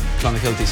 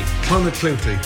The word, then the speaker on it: Clonakilty: Irish tea bags or Clonakilty sausages from Cork. – Clonakilty. – Clonakilty. – Clonakilty, thank you.